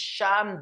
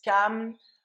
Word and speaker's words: Cham: Alors, certains disent Cham, d'autres disent Cam. En fait, en hébreu, c'est Ham, comme en anglais. Bon. Je vais dire la prononciation Cham, d'accord? On va partir sur ce principe-là, parce qu'il y a plusieurs prononciations.